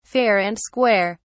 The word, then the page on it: fair and square: British English